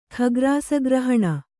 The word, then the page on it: ಖಗ್ರಾಸಗ್ರಹಣ: khagrasa grahaṇa